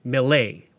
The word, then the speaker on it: Millet: Now, I'm going to be pronouncing his name Millet, because he was a French fur trader, and that's likely how it was pronounced.